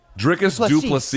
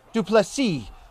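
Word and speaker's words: Du Plessis: Luke, big moment on the undercard for, please pronounce my name correctly, Drikus Duplessis is how I understand it. – Duplessis.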